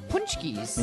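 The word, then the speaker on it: pączki: Yes, and she brought us her homemade punchkis.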